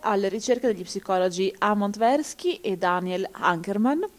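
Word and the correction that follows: Kahneman: che no, scusate, Kannerman.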